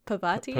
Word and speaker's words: Parvati: It's Parvati. – Parvati? – Yeah.